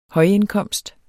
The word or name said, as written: højindkomst